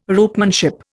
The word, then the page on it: ropemanship: Indian